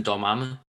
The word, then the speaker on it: Dormammu: is it Morgamo? I can't see it. – Do you mean Dormammu? – Oh, Dormammu, that's it. Sorry, I knew I was saying it wrong.